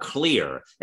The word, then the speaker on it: Xlear: There's another product called Clear. It's spelled X-L-E-A-R.